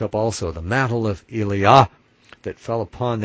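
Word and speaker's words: Elijah: And I'm putting the accent on the Yah in Eliah, even though in the King James it says to put it on the second syllable, like Eliah. It should actually be Eliah, to emphasize that our Father's name is in our name too.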